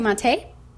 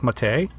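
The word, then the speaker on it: Maté: Is that how you say it when you had the little accent on the E? – I would say Matee. – Matee? – Mm-hmm. – All right, Rudolf Matee.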